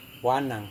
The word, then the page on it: wanang: Serewen